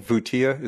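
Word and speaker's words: Voutia: Just so the audience knows, the name Vutia, is that how it's pronounced? Mm-hmm. Vutia? – That's right. – Yeah, it's spelled V-O-U-T-I-A. V-O-U-T-I-A, Vutia.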